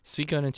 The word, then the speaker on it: Suikoden: It's Suikoden 2. How do you pronounce it? – Suikoden 2, yeah.